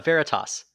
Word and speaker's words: Veritas: Veritas. – Is that how it's pronounced? – Yeah, well, there you have it.